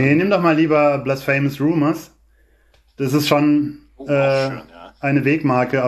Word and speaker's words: Blasphemous Rumors: Das war Blasphemous Rumors von Depeche Mode, was ich diesmal richtig ausgesprochen habe, wie mir mal meine beiden Mitstreiter gerade bestätigt haben.